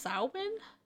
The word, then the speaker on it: Samhain: It was a Gaelic word pronounced Samhain.